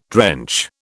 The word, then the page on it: drench: British English